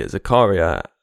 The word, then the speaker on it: Zakaria: But the other players in that group that got into Path to Glory are the Zakaria and Yusidja. And that is the correct pronunciation. I did look it up.